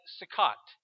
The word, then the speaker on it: Sukkot: In Hebrew, it's pronounced somewhat like Sukkot.